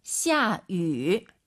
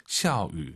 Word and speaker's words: 下雨: 下위. 위 아니죠? – 下雨. – 입술을 동그랗게 말아서 위 하려고 하세요. 下雨. 샤우. 네, 요렇게 발음하셔야 됩니다. 절대 위, 입이 가로로 찢어지면 안 됩니다, 여러분.